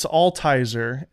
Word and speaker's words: Altizer: Well, it's kind of obvious when when people are dyslexic because they it's Altizer, A L T I Z E R, and I don't even know if that's how you're supposed to say it.